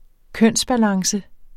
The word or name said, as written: kønsbalance